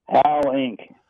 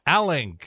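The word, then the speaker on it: Alink: Al Ink, like red ink, Al Ink. – Al Ink, all right.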